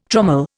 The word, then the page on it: trommel: Indian